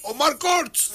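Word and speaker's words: Omar Courtz: Yo decía, ¿cómo se pronuncia? Ya escuché que Wisin le dice Omar Courts.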